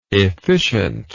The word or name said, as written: efficiently